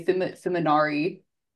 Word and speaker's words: Fimognari: Just say it, and the G is silent, so just say Fimognari.